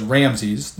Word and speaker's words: Ramses: I will pronounce the name in this episode as Ramses, though contemporary scholars now tend to spell it as Ramesses, R-A-M-E-S-S-E-S. The name, however you say it, refers to praise to the sun god Ra, who had resumed some popularity in the post-Akhenaten period.